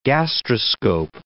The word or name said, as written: gastroscope